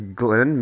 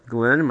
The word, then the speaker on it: Glenn: Yes, my name is Glenn, Mr. Glenn. Glenn, my first and last name are spelled the same. GLENN, two N's on H. Glenn Patrick Glenn.